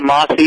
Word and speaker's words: Amasai: You're pronouncing how it's spelled. We call it Amasai, but we wanted to spell it that way to give honor to the Maasai tribe.